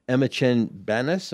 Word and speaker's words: Emma Chen-Banas: Well, let me turn to you, Emma Chen-Banas.